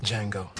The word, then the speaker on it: Django: Django. The D is silent.